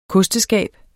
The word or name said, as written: kosteskab